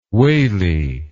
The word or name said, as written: waley